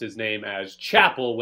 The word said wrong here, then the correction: Chappelle: when in fact it is Chappelle.